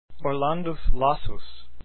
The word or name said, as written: Lassus